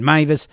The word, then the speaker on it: Meiwes: FYI, his name is spelled M-E-I-W-E-S, just in case you want to search for him, but of course it's pronounced Meiwes.